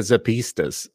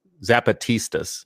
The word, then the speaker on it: Zapatistas: And the same thing with the Zapatistas of Mexico. Now I'm saying the name wrong. Zapatistas.